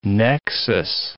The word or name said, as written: nexus